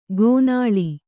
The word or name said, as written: ಗೋನಾಳಿ